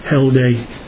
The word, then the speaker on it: Heldai: So the name in Hebrew, if you look it up in Strong's 2469 there, you'll see it's like Cheldai or Keldai. C-H-E-L-D-A-Y. I don't think he actually pronounced the C-H. I think it is Heldai.